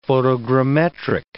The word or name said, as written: photogrammetric